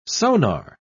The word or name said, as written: sonar